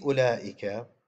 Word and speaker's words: أولئك: الإخوة السادة الأحبة الكرام، نحن اليوم نشرع بإذن الله تعالى في الأسماء الموصولة، ولا غير من تلويح لأمر هام في أسماء الإشارة، ألا وهو عندنا في أولئك الواو هنا تكتب ولا تلفظ، تكتب ولا تلفظ، فنقول أولئك لا نقول أولئك.